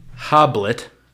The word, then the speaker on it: Hoblit: Directed by Gregory Hoblit. Interesting last name. – Hoblit. – Hobli, I believe is the French pronunciation.